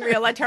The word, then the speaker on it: realtor: Can you please stop saying realtor? – I said realtor. – Realtor. – Realtor. – It's two syllables.